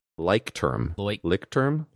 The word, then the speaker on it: Leuchtturm: Leuchtturm. Pretend it's an O-Y.